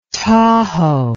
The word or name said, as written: tahoe